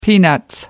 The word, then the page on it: peanuts: British English